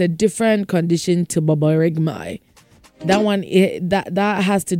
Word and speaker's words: borborygmi: That is baboregmai. It is spelled B-O-R-B-O-R-Y-G-M-I. Baboregmai.